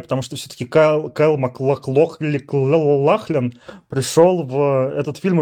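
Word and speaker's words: MacLachlan: Ну вот, например, а, Кайл Макло... – Маклахлен. – Маклахлен. Маклахлен, вот это вот.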